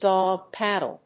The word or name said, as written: dog‐paddle